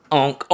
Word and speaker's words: ankh: So, Logan has an ankh. Is that the pronunciation? – Ankh. – Yeah, but an ankh. – Onk, onk. That's right.